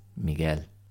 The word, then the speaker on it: Miguel: My Spanish-speaking friend reached out to me and said, listen, by the way, there's no U sound in Miguel, which I think is how I was pronouncing his name before. She's like, it's just Miguel.